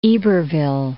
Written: Iberville

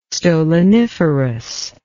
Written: stoloniferous

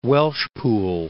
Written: Welshpool

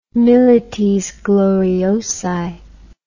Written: milites gloriosi

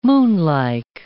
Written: moonlike

